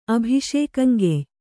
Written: ಅಭಿಷೇಕಂಗೆಯ್

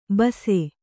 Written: ಬೂಸಿ

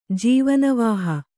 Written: ಜೀವನವಾಹ

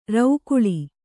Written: ರೌಕುಳಿ

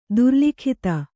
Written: ದುರ್ಲಿಖಿತ

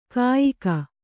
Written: ಕಯಿಕೆ

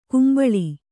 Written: ಕುಂಬಳಿ

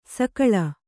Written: ಸೊಗುಳಿ

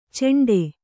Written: ಚೆಂಡೆ

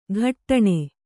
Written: ಘಟ್ಟಣೆ

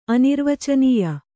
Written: ಅನಿರ್ವಚನೀಯ

